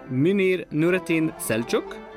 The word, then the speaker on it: Münir Nurettin Selçuk: His father, in turn, was the composer and tenor singer, Münir Nurettin Selçuk.